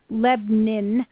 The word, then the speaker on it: Lebanon: I've been schooled that I don't pronounce it correctly. It's supposed to be Lebanon. But I'm more phonetic than that.